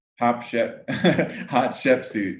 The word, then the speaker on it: Hatshepsut: Is that how you say it? How do you say that name? – Hatshepsut, yes.